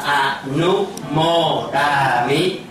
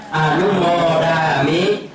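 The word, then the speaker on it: anumodāmi: Chữ này đọc là A-nu-mô-đà-mi. Mọi người đọc theo A-nu-mô-đà-mi. – A-nu-mô-đà-mi.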